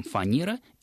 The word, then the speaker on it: фанера: Все-таки интеллигентные люди знают, что и в том, и в другом случае произносится мягкий «н». То есть правильно мы говорим «фанера» и «шинель».